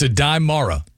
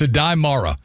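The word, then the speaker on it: Aday Mara: It's Adai Mara. Sorry, some of the country comes out sometimes. Oh, Adai Mara. – Just think of Joseph Adai.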